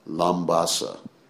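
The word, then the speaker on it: Labasa: Then we go to another island, which is called, the island is called Vanu Leva, but the city is called Lambasa. You pronounce the B like an M, MB or something like that.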